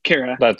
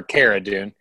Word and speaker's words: Cara Dune: I mean, because like Clara Dune, am I saying that right? – Cara. – Or Cara Dune.